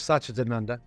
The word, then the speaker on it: Sachidananda: I know you have a spiritual side to yourself with your relationship with Swami Sachinananda. Is that the correct pronunciation? – Close, Sachidananda. Uh-huh.